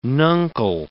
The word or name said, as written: nuncle